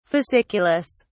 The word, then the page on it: fasciculus: British English